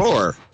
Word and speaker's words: drawer: I like the way you pronounce that word. – Draw? Is that wrong? – Drawer is drawer? What I think. Yeah. But anyway, go ahead. – I'm from England. Drawer. So it has two drawers, either side by side or one above each other.